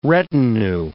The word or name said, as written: retinue